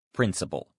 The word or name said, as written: principle